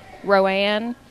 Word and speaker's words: Rowan: Bertie, Rowan.